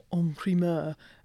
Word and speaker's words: en primeur: So for the benefit of those wannabe wine buffs listening, can you start by explaining what en primeur actually means? And also tell me if I'm pronouncing it properly. – I think you are.